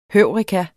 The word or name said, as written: heureka